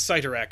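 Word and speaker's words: Cyttorak: They're technically the Crimson Bands, but I'll the fact that you pronounce Sitarak correct, I'll let it slide.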